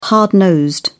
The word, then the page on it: hard-nosed: British English